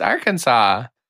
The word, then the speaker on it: Arkansas: Is that not how you say it? – Have you never heard of how to pronounce that state? – I don't think so. – It's Arkansas.